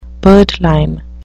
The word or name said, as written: birdlime